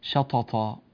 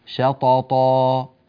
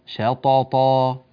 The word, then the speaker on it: شططا: فنتنبه بارك الله فيكم إلى مخرج الطاء هنا، ولا ينبغي أن تهمس الطاء، يعني لا نقول شططا طط طط طط، هذا الصوت غير صحيح في الطاء. الطاء حرف مجهور، يعني لا يصحبه نفس يخرج معه في أثناء النطق. شططا شططا بهذه الطريقة، ربنا يبارك فيكم ويكرمكم يا رب.